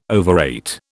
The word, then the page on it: overrate: British English